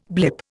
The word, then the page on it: blip: Indian